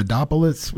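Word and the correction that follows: Anthopoulos: What's the Anthopolis? – Anthopolis.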